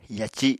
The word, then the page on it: yaci: Apatani